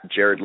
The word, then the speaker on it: Jared: It's at Jared Lichtin. And I'll say how to spell that real quick. It's J-A-R-E-D, just like Jared from Subway. I don't like saying that anymore, but it's J-A-R-E-D.